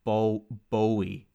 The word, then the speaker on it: Bowie: His stage name is pronounced Bowie, like to rhyme with Joey.